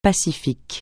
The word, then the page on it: pacifique: francais